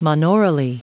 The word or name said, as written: monaurally